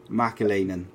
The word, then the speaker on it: McAleenan: Mcaleenan.